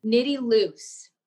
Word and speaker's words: Nitty: The question I had was, how did you pronounce Nitty's name? – And didn't just go through the name pronunciation. She explained what all the names meant. – Back. – Nitty Loose. – Loose. Okay. Yeah.